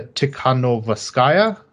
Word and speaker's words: Tikhanovskaya: The opposition candidate, whose name I am going to struggle to pronounce here, Tikhanovskaya. Yeah, there we go.